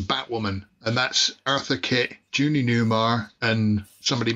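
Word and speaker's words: Julie Newmar: The woman on the way, if you go back up, Phil, Julia Neymar. That's Catwoman at the Batman. – Judy Newmar. – That's how you say it, sorry.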